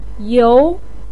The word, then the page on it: you: 2